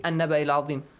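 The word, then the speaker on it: النبإِ العظيم: Now, do not read an. Read an-Naba'i al-Azim, the rest.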